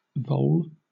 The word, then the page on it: vole: British English